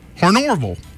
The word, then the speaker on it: Honorville: It's spelled Honorville. H-O-N-O-R-V-I-L-L-E. Hornorville. And I, where the R comes in, but it's there.